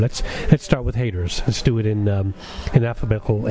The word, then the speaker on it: H8RZ: I say that because the title is actually spelled H-8-R-Z, but pronounced Haters.